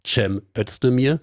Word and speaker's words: Cem Özdemir: erstmal Cem Özdemir, bis dann auch viele gelernt haben, nein, das spricht man weicher im Türkischen.